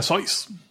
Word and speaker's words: Seuss: Se pronuncia Seuss. Según él.